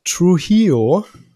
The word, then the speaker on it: Trujillo: Robert Trujillo von Metallica, so wird der Name ausgesprochen.